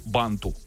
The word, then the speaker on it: банту: Да, Татьяна из Москвы написала: «Мой последний звонок я запомнила по банту». Угу. Правильно же ударение поставил? – Да.